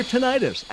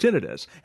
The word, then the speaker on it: tinnitus: It's your tinnitus, actually pronounced tinnitus.